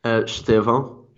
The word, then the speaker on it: Estêvão: Estevao.